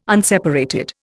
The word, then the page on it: unseparated: Indian